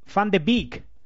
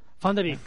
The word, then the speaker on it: Van de Beek: Es Van de Beek. – Van de Beek.